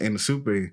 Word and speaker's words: Anisupe: So I had to keep asking him, and then he was like, it's, pronounced Anna Super.